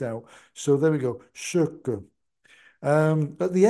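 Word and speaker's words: Sökö: But what makes it tricky is that both of the letter O's in that word have an umlaut over them, which makes the pronunciation "sucker."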